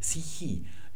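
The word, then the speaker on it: Psychi (ψυχή): Für die Psyche im Altgriechischen sagt man Psychi im Neugriechischen. Das ist also immer ein I.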